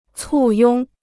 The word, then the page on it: 簇拥: cù yōng